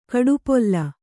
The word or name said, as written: ಕಡುಪೊಲ್ಲ